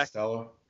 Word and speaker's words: Costello: But when I tell saying they took a dump on their chest, I'm specifically talking about uh, Costiello. Is that how you pronounce his last name? – Kid from Stanford, KJ quarterback. Costello. – Costello. Eh, yeah, there we go.